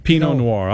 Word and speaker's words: Pinot Noir: Pinot Noir. – Yeah. – Am I saying that correctly? – Yep. – Pinot Noir.